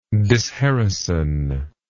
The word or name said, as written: disherison